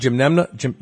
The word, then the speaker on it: Gymnema: how do you pronounce it? – Gymnema.